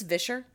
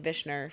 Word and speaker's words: Vischer: Yeah, it's Vischer. Ooh. And I think I've been saying Vishner for a very long time. – Whoops. – Anyway, Phil Vischer, I go back to, you know, him saying, when society pushes Christians to the sidelines, we should be going quietly.